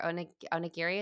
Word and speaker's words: onigiri: onigiri.